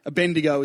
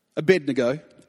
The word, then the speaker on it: Abednego: And upon Daniel interpreting King Neb's dream, Shadrach, Meshach, and Abednego, depends on how you want to pronounce it. I see some people snicker. Abednego is how I grew up saying it, but, you know, Abednego.